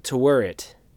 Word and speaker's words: Taweret: Uh, but God, the hip hop uh, Tawert, uh, Tawerit, T A W E R E T, Egyptian god.